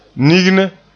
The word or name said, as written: nigne